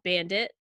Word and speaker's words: BVNDIT: And this week, we're talking about BVNDIT. That's how it's pronounced, but it's spelled BVNDIT.